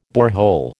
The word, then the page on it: borehole: American English